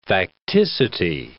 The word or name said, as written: facticity